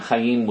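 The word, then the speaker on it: Haim: And then Corey Chaim, you know, which of course I'm saying Chaim with the Hebrew accent, Hebrew pronunciation, because that's his real name.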